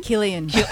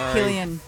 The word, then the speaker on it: Cillian: We have Cillian Murphy. Is that how you say his name? – Killian. – Killian. Screwed it up.